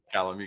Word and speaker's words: Calame: Calame, you got it.